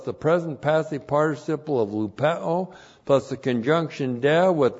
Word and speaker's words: lupeo: Lupeo looks like this in the Greek. L-U-P-E, long O, lupeo.